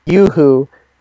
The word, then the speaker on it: Yoo-hoo: I'm pretty sure that's pronounced Yoo-hoo, and you just said Yahoo. Yes, there, Greg. You said Yahoo.